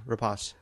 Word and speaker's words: Rapace: And the main two stars of this movie are Noomi Rapace. I don't know how to say her name. – Rapace.